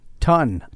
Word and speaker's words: Tun: Tun. On the air, yeah. My name is spelled J-U-S-T-O-N. – Like a ton of bricks.